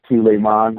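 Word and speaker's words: À tout le monde: And my French is terrible, so but it's a Tous Les Mans, I think. Is that how you say it? Is that how you say it, Richard? – Yeah, more or less.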